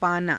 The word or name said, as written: paanaa